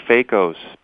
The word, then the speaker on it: phakos: It comes from the Greek word phakos, P-H-A-K-O-S, which means lens.